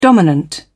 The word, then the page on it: dominant: British English